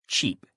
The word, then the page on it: cheap: male